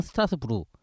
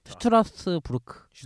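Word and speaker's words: Strasbourg: 그다음에 독일어로는 슈트라스부르크 이렇게 이렇게 되는데 진짜 그렇게 해요? – 근데 가끔 이제 스트라스부르크라고 하는 경우가 있어요. 독일어도 불어도 아닌 이렇게 불어로는 이제 스트라스부르. – 그렇죠. 그 끝에 발음을 발음하지 않아요.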